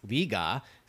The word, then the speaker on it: Liga: So Liga is pronounced Liga.